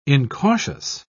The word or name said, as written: incautious